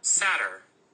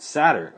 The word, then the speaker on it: satyr: Satyr. – That's how you pronounce it. – Satyr, okay.